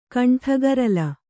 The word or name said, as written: ಕಂಠಗರಲ